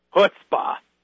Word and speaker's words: chutzpah: That's what we call chutzpah. Or right. – If you say it correctly, let me get my throat just right. Chutzpah.